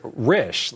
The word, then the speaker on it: Risch: Patrick Risch rhymes with fish.